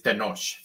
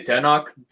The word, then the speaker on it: Tenoch: So the correct pronunciation is Tenoch, which is the original Nahuatl pronunciation. I say Tenoch because it's easier for English-speaking readers, sure, to pronounce it.